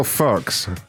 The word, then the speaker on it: Fuchs: So her last name, her last name is Fox, but it's spelled F-U-C-H-S. But that can only spell Fox. – Fox, yeah, that's how you pronounce it.